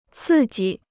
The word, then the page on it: 刺激: cì jī